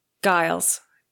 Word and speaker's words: Giles: Giles. Oh, yay, I'm doing it right.